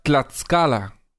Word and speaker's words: Tlaxcala: Cintia Berenice dice que se pronuncia Tlaxcala.